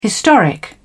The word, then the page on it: historic: British English